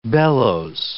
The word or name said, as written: bellows